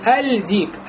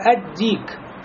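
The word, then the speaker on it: Ад-Дик (الديك): Например, «Дикун» – петух, если с алифлям будет, будет не «Аль-Дик», а «Ад-Дик», «Ад-Дик».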